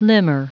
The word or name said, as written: limner